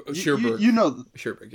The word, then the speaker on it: Cherbourg: Umbrellas of Cherbourg. – Cherbourg, yeah.